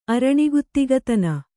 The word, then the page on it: ಅರಣಿಗುತ್ತಿಗತನ: araṇiguttigatana